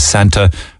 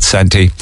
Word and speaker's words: Santa: I think 60% of people who responded said the correct pronunciation is Santa. 40% came up with Santy.